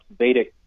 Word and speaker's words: Vedic: I'm impressed, by the way, that you you pronounce Vedic correctly. A lot of people, surprisingly, they call it Vedic, so it shows me that you know a little something about ancient Indian culture.